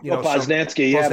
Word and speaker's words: Posnanski: I believe I'm pronouncing his last name, but a journalist. You may know him. – Posnanski. Kansas City.